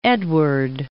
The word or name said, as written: Edward